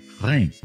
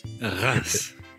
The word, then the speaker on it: Reims: He goes, "It's it's Rhin." – Reims. Reims. So don't say that.